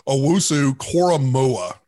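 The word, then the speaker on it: Owusu-Koramoah: Owusu Koramoah. Did I even get that close? – That's it. You nailed it.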